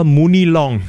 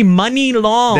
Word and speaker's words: Money Long: I've been calling her Mooney Long. – Mooney Long. – No, it's actually Money Long.